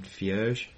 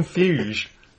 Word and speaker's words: Feige: I'd quite like to be, I'm going to pronounce this certainly wrong, Kevin Feige, the Marvel guy. Makes me sound very German. – Kevin Feige, I think his name's pronounced.